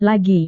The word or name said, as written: lagi